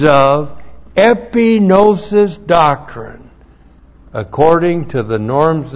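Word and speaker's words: epinosis (ἐπίγνωσις): caused to be renewed in his thinking because of epinosis doctrine in his heart. E-P-I-G-N long O-S-I-S.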